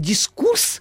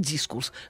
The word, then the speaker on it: дискурс: Я действительно возмущалась, когда говорят дискурс. Но мне показали недавно, уже допустимо, два ударения.